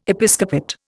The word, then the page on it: Episcopate: Indian